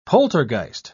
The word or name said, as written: poltergeist